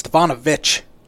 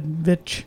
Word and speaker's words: Stefanovic: It's Stefanovic. – I said Vich. – You said Vic. – Oh, my bad. Well, spelled like that at the end. There's no H on the end.